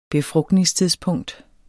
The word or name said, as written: befrugtningstidspunkt